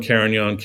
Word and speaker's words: Carignan: So Cinsault and Carignan. Carignan's got a silent G in it, which also throws people off, you know.